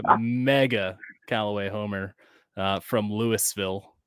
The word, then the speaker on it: Louisville: So first and foremost, what we have to get out, it's not Louisville, Louisville. – Louisville. – Gotta say it right.